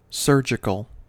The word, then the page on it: surgical: American English